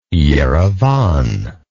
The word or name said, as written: Erivan